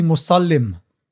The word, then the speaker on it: Musallim: It's actually Musallim. – All right. – So it's Kaske, right? Yeah. It's pronounced with an Addak. – Yeah. – So it's not Muslim, it's Musallim.